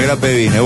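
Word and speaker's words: Grapevine: ¿Qué se llama el Hate Intrude de Gravapine? Palermo Hollywood, y las delicias Hollywood pronuncian de esa manera. Kelly Truin de Grabar Pine, algo así. De Grape Vine, bueno.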